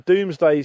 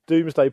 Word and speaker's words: Domesday: Because it's spelt Domesday. For people who aren't of the UK listening and don't know what the Doomsday book is, it's spelt Domesday, D-O-M-E-S-D-A-Y. But on this Doomsday part of the BBC website,